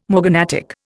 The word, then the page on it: morganatic: Indian